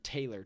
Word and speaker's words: Taylor: That's M-C, capital L-E-A-N-A is my middle initial, and then Taylor, T-A-Y-L-O-R, is my last name.